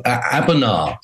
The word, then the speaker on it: Abona: It's a Portuguese verb, Abona. So Abona, we chose our operational names due to themes, and they changed every month.